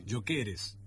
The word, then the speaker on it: Gyökeres: Gyökeres, ya está, rebautizado.